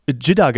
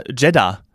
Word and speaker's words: Jeddah: Jetzt hat die Sandy eben Jidda genannt. Ich sage ja immer Jeddah.